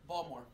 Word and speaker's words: Baltimore: Baltimore. Pronounce that word. – Balmer. – Balmer. – Balmer. – B-A-L-M-E-R. Yep. – Okay. Gary, pronounce that word. – Picture. – Picture. P-I-X.